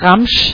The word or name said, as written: zboží